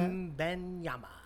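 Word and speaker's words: Wembanyama: Wembley? Do we know the right way to say that? – Wembenyama. – Victor. – Yeah, Victor. There you go. I'm sticking with Victor.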